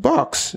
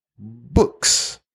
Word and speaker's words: books: She said, I like to read box. Box, yeah. I said, huh? She likes to read books, not box, right?